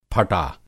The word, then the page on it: फटा: پھٹا